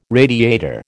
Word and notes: radiator: American English

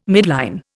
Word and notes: midline: Indian

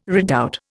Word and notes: redoubt: Indian

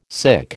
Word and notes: sic: American English

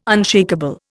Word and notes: unshakeable: Indian

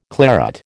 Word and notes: claret: American English

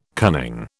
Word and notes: cunning: British English

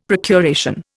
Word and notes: procuration: Indian

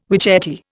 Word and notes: witchetty: Indian

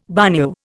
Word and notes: bagnio: Indian

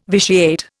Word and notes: vitiate: Indian

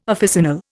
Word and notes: officinal: Indian

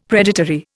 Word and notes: predatory: Indian

